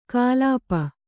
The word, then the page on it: ಕಾಲಾಪ: Kannada